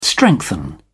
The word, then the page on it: strengthen: British English